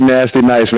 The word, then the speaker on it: Naztee Nyce: DJ Nasty Nice, man. Let me spell it to y'all, man. DJ NAZTEE. NYCE. Once again, DJ NAZTEE NYCE. Please don't spell my name like a stripper, y'all.